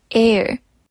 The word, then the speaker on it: heir: 记得 H 不发音，所以前面如果要加冠词的，要变成 the heir。